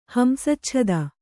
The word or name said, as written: ಹಂಸಚ್ಛದ